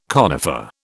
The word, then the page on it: conifer: British English